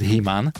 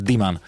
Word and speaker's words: Dhiman: Píše to, že Himan. – Áno. – Ale takže sa to vyslovuje Diman.